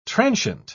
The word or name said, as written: transient